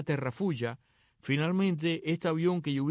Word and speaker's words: Terrafugia: Vi este vehículo, este Terrafuya, es como se escribe, Terrafugia.